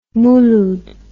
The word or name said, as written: Mouloud